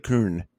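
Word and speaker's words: Köln: The way I was explained to me is that the Germanic, like, or the way it's spelled out, would pronounce as Kuhn. Of course, all those English-speaking Americans, we say Cologne, but I was just being Kuhn.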